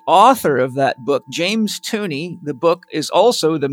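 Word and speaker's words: Tunney: Tooney rhymes with money, funny.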